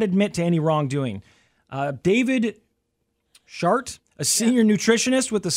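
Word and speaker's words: Schardt: S-C-H-A-R-D-T. I know. It sounds like, right? – Yeah.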